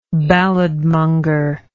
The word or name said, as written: balladmonger